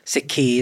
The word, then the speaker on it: Sikhi: It's Sikh, Sikhi is how we pronounce it in Punjabi, the word means learning.